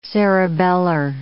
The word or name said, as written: cerebellar